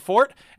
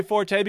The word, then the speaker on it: forte: But George Carlin is the one who taught me about the pronunciation of fort. And so he said, I know. The dictionary says it's okay to say forte because of popular usage. You know what I say? Fuck popular usage.